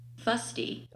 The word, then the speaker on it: fusty: So I'm going to play it real quick so everybody can hear how it's pronounced because footsie? Footsie. Fusty. Oh, fusty. Sorry.